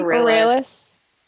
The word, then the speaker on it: Aurelius: How do you say it? – Aurelius. – Yeah.